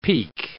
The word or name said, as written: peke